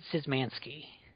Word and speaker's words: Szymanski: How do you pronounce his name again? – David Szymanski.